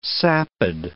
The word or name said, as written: sapid